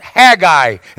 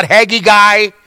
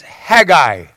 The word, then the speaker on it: Haggai: His name is Haggai. That's how it's pronounced. Haggai. It's not Haggy Guy. It's Haggai.